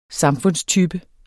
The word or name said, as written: samfundstype